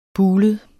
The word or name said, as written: bulet